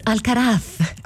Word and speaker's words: Alcaraz: Innanzitutto Alcaraz comunque è la pronuncia esatta è Carlos Alcaraz. – Ah, cioè te devi mangiare la lingua mentre lo... – Sì, Alcaraz. Cioè devi sputare, ok.